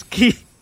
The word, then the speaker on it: xi: It's XI, oh. – Which is pronounced as key.